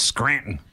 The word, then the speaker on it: Scranton: Hey, you pronounce the T. It's Scranton. – Scranton. You got to grunt.